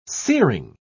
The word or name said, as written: searing